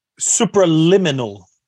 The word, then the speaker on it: Supraliminal: Donc, l'album de Nothingness s'appelle Supraminal. Supraliminal. Su Supraliminal. Mm-hmm. J'étais tellement proche, il manquait une syllabe.